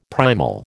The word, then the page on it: primal: American English